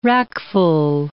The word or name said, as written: rackful